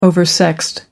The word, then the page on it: oversexed: American English